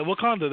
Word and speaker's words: Wakanda: Yeah, Wakanda, that's right.